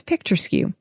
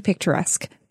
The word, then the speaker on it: picturesque: is picture skew. It's actually picturesque.